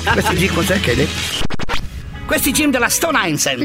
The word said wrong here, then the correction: Stone Island: Questi jeans della Stone Islands. – Stone perché scrivono come si legge? – Si chiama così, Stone Island.